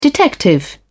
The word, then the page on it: detective: British English